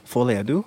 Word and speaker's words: Folie à Deux: So the band released their next album, Folie à Deux. I hope I'm saying that right. Folie à Deux. – Folie à Deux. – Folie à Deux. Okay, there you go.